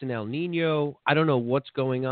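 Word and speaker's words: El Niño: El Nino. No, that was good. – El Nino, yeah. All right, cool. – Three syllables. You did a good job.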